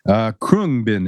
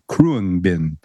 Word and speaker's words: Khruangbin: Krungbin is the name of the band that she was talking about. And I'll spell the name for you. It's K-H-R-U-A-N-G-B-I-N. Krungbin, I believe is how it's pronounced. K-H-R-U-A-N-G-B-I-N.